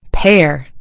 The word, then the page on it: pair: American English